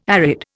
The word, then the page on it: arid: Indian